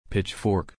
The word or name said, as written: pitchfork